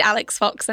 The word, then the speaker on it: Alex Fox: I am indeed Alex Fox, a name that quite conveniently rhymes with Phallix Cox, which